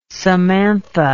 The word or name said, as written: samantha